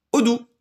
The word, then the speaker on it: Odoo: J'avais Boon Manager euh, récemment et là j'ai Odoo. Odoo. Alors Odoo, ça s'écrit O-D-O-O. o d o o c'est ça C'est exactement ça. C'est un mot tout doux finalement.